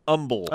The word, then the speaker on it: Humble: This listener says one of the most annoying Texas town names is Humble, Texas, pronounced Humble. – Oh, the Texas. – H is silent.